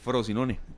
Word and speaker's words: Frosinone: Frosinone.